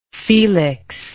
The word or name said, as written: felix